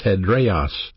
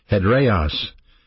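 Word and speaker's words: hedraios: It's pronounced hedreos, hedreos, and it means unmovable.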